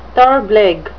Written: therblig

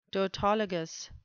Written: tautologous

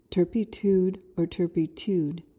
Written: turpitude

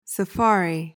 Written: safari

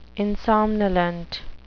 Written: insomnolent

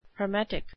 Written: hermetic

